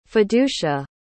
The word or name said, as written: fiducia